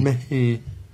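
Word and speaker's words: Merhi: Mehe. It rhymes with mercy.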